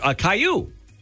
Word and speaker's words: Caillou: Kalalil? – Oh, Caillou. – Caillou. It's C-I-L-L-O-U.